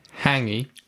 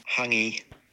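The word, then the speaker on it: hāngī: So, hangi. No, I pronounced that wrong. – Hangi.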